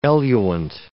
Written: eluant

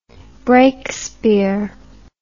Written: breakspear

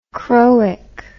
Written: chroic

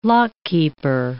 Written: lockkeeper

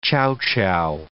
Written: chow chow

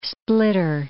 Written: splitter

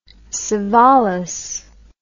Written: savalas

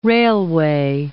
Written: railway

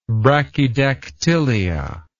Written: brachydactylia

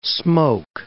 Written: smoke

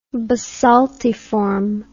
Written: basaltiform

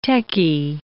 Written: techy

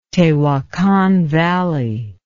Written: Tehuacan Valley

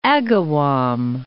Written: Agawam

